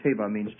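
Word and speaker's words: Teva (תבה): Now, the Hebrew word for ark is T-E-V-A-H. Teva. And stressing the last syllable usually, teva.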